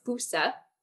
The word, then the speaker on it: fossa: called the fusa, and that's spelled F-O-S-S-A if you want to look it up.